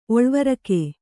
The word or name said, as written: ಒಳ್ವರಕೆ